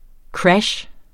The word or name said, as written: krash